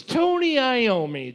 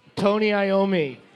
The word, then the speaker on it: Tony Iommi: It's Tony Iommi. That's how it's pronounced. – A, Aerosmith. B, Black Sabbath. Or C, Thin Lizzy. How is it pronounced? – Iommi. Tony Iommi.